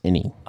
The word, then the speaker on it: ini: That ini, really? – It's pronounced any.